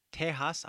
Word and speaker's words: Tejas: Und schließlich Provincia de Tejas, aber mit X geschrieben. Beide mit J oder X werden von Mexikanern als H ausgesprochen. Also Tejas ist T-E-J-A-S, aber Tejas ist auch T-E-X-A-S.